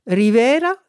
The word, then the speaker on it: Rivera: Ça s'écrit Y-E-L-A-N-Y. Rivera, ça s'écrit R-I-V comme Victor, E-R-A.